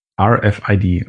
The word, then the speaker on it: RFID: In ihr sitzt der RFID-Chip oder muss man das Englisch aussprechen? – Ja, Englisch. RFID. – Nee, dann R. – RFID, okay.